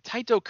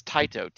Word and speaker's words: Tite: It's weird because it's spelled T-I-T-E, so it's like tight or tighty, but in like the phonetic spelling, it says Taito, T-A-I-T-O. So it's like, okay, Taito Kubo.